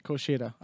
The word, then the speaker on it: Kushida: Gargano versus Kushida. – Kushida.